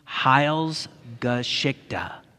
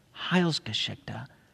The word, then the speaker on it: Heilsgeschichte: Heilsgeschichte. Heilsgeschichte.